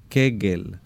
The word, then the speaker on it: Kegel: Kegel. Se escribe K-E-G-E-L.